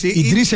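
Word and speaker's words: Idris: Αυτή είναι η Ζαΐν με το Μακεμπά και διάβαζα μια συνέντευξη του Ίντρις Ελμπά, του πολύ γνωστού αυτού ηθοποιού. – Τον έκανες Γάλλο έτσι. Άιντρις Ελμπά. – Ίντρις Ελμπά, δηλαδή.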